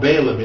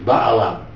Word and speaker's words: Balaam: His name's called Balaam in our pronunciation, but the Hebrew is Baalam.